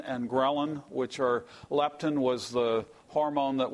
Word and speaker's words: ghrelin: ghrelin.